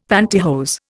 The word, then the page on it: pantihose: Indian